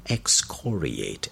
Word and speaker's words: excoriate: E-X-C-O-R-I-A-T-E. Note the pronunciation on the second syllable. Excoriate. Excoriate.